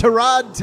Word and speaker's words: Tyrod: Don't mispronounce his first name. – His name is Tyrod Taylor.